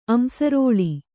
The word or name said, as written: ಅಂಸರೋಳಿ